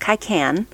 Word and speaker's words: cycene: So Old English spellings were C-Y-C-E-N-E, pronounced Kaikan, and K-Y-C-E-N-A-N, pronounced Kaikana. Kaikanan.